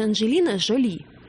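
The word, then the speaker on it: Jolie: Анджелина Джоли, которую все называют Анджелина Джоли, делаю ударение вот так, хотя надо говорить Анджелина Джоли. Потому что ударение должно... – В Википедии Джоли написано. – А потому ударение. Что когда родилась Анджелина Джоли, ее родители заметили, что девочка очень симпатичная, и называли ее Жоли, симпатичная, милая. И поэтому она Анджелина Жоли.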